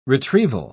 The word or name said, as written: retrieval